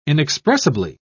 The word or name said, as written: inexpressibly